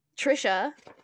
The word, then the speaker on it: Trisha: by Tracy. Can you help me with this name? – Trisha.